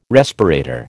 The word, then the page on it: respirator: American English